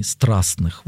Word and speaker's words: страстных: Слышите, вот я даже в христианстве встречал таких людей, которые, ну, может быть, шарахнулись от каких-то страстных переживаний, страстных, вот, наверное, правильнее так ударение поставить,